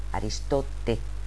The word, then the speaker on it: Aristote: Aclarida la confusió, vaig descobrir que aquell personatge que a casa nostra coneixem amb el nom d'Aristòtil, a França s'escriu amb una E final, Aristote, però es pronuncia Aristot.